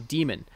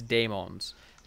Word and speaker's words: daemon: Like I'm almost certain the correct way is daemons. Like that AE symbol is A.